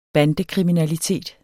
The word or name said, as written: bandekriminalitet